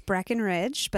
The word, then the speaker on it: Breckenridge: It's Breckenridge, but that's my handle for Instagram.